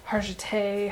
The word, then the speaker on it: Hargitay: You know, a lot of projects, you know, I think we talked about Law & Order because of the main character, Mariska Hargitay.